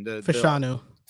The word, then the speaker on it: Fashanu: Latham or a Olu Fashanu. I don't want to mispronounce his name. – Fashanu.